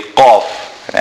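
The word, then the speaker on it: ق: The surah starts with a qaf. As we mentioned, Qaf is unlike Kaf or unlike K, but Qaf is pronounced from the back of the mouth.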